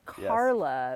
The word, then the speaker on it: Carola: It's Carla, yes. But it's not spelled like that. – Yes. I'll start with the confusing bits first, but I'm David Hendrickson. I run Carla Wine and Cider.